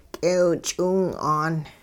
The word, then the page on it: k'eew-ch'iwing'aan: hupa